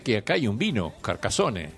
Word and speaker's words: Carcassonne: Me fui primero al sur de Francia, a una ciudad que se llama Carcassonne, así pronuncian ellos, que es la ciudad medieval mejor conservada de Europa. – Carcassonne.